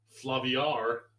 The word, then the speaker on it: Flaviar: So I have a three for free for Flaubert. – Flaubert. – I guess it's spelled Flaviar, but I guess I'd say Flavier. – Oh, Flavier. – I don't know why. – Flaviar.